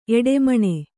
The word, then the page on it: ಎಡೆಮಣೆ: kannada